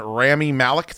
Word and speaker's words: Rami Malek: So John David Washington, Rami Malek, did I say his name right? – Yeah.